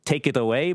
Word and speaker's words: tekeraoi: It's spelt like this, T-E-K-E-R-A-O-I, but it's not pronounced that way, as you can imagine. It sounds kind of like take it away, but you say take it away.